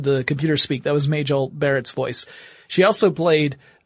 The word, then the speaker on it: Majel: And the project name at Google was Majel, or Majel, depending upon how you want to pronounce it. But Majel would be the way her name was actually pronounced.